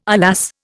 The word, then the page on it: alas: Indian